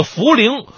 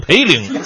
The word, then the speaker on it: 涪陵: 重庆的涪陵，我以前一直念的是培陵，直到后来出了个涪陵榨菜，才让我记住。